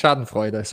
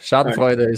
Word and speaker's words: Schadenfreude: It's Schadenfreude, so you're really good. – All right. – Schadenfreude is perfect.